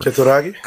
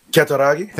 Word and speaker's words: Kutaragi: PlayStation creator Ken Kutaragi. Is that how you say it? Kutaragi? Sure, whatever. – Kutaragi? – Okay. Say that again? – Kutaragi? – Thank you.